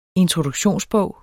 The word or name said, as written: introduktionsbog